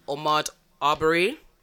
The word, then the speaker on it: Ahmaud Arbery: They're dealt with the same way that Ahmaud Arbery, is that how you pronounce it? – Mm-hmm.